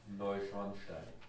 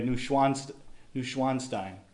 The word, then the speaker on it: Neuschwanstein: Neuschwanstein. – Okay. I usually said it the American way, Neuschwanstein.